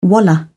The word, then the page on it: wallah: British English